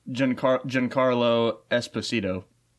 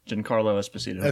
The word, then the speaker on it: Giancarlo Esposito: Giancarlo Esposito. Giancarlo Esposito.